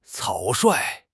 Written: 草率

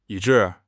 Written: 以致

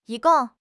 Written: yi1 gong4

